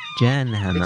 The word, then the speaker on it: Jan Hammer: Jan Hammer. – It's a hard J. – So it's not Dutch? – It's not Dutch.